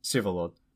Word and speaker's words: Vsevolod: And for those of you at home who are like driving, it's spelled Vsevolod, Sivolod, which is kind of difficult for Westerners to pronounce because it's an odd spelling, odd pronunciation.